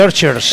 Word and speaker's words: Archers: El otro día los puse y lo miré, porque es Archers es una de las pocas palabras en inglés que la CH se pronuncia bien. – Como che.